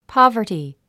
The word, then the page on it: poverty: pάvə r ti; パヴァティ